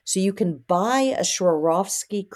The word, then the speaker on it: Swarovski: How do you pronounce that? Swarovski? – You got that right. Yeah, Swarovski.